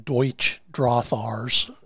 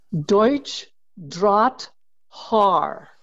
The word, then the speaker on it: Deutsch Drahthaar: And and just for the record, one of my pet peeves is how many people call them Deutsch Drothars. And uh, let's just uh, from a from a judge in the German system, please pronounce the dog's breed name correctly. Deutsch Drahthaar. German dropped wire ha. hair.